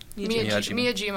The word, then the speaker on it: Miyajima: Well, I think one of my personal favorites was we went to, help me with the pronunciation, Miyajori Island? – Miyajima Island.